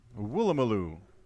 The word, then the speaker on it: Woolloomooloo: Feel free to pronounce that. – Oh my gosh. Woolloomooloo. – Nailed it.